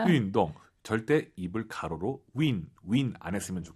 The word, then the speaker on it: 运动: 자, 발음하실 때, 입을 가로로 벌려서 win, 우리가 흔히 알고 있는 그 영어의 win, you win 할때그 win 발음이 아니에요, 사실은. 운동은 어렵게 발음하셔야 돼요. 운동이 어렵잖아요. YU 발음은 입술을 동그랗게 말아서 운동 이거예요.